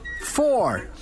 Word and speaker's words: four: Isso aí, meat com a grafia M-E-A-T significa carne, enquanto se escrevermos M-E-E-T significa conhecer. Então, meat pode significar carne ou conhecer. Depois temos a palavra for. For, escrito F-O-U-R, significa quatro, enquanto que F-O-R significa para alguma coisa, como em This is for you. Depois temos Here. Here, escrito H-E-R-E, -E, significa aqui, enquanto se escrevermos H-E-A-R, significa ouvir.